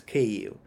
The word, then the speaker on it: Kyiv: So I have to kind of mentally stop for a second and make it doubly clear that it's Kyiv.